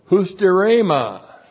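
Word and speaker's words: husterema (ὑστέρημα): And the direct object of pros ana plerao is the accusative plural of husterema. Looks like that in the Greek, H-U-S-T-E-R, long E-M-A, husterema.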